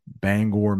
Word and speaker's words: Bangor: How far is Bangor? That's how we pronounce that, by the way, in Maine. Bangor, Maine.